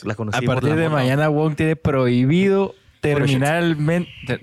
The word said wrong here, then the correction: terminantemente: Terminantemente, prohibido. – terminalmente, terminantemente prohibido tomarle fotos a chavas.